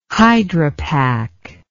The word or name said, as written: hydropac